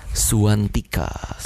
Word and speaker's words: Suwantika: Suwantika.